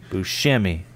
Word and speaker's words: Buscemi: Is it Buscemi or Buscemi. – Buscemi. Sorry, Buscemi. – Buscemi? – Buscemi. – Buscemi. – Buscemi. – Yes. There we go.